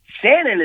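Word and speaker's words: Shannyn: Shannon is spelled with a Y, and so that's where a lot of there it is.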